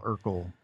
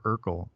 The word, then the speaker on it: Urkel: But yeah, so thank you, Wikipedia, and thank you, Micah, for introducing us to Family Matters and the Winslows and their neighbor Urkel. – Right, which rhymes with circle.